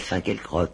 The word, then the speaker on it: Finkielkraut: Maintenant, monsieur, il y a le philosophe, là, moi, je l'appelle Finkielkraut. Je prononce tout à la française.